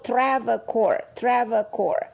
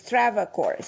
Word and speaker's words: Travacor: It's called Travacor. Exactly the way you hear it. It's Travacor.